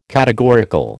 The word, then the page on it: categorical: American English